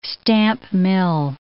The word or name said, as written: stamp mill